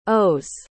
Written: owes

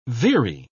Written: veery

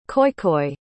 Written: khoikhoi

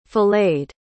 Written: filleted

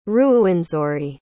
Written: ruwenzori

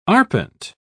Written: arpent

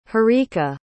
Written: harika

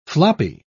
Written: floppy

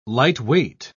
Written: lightweight

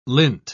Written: lint

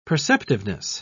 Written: perceptiveness

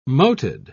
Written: moated

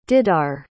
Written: didar

